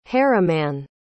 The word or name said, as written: harimanne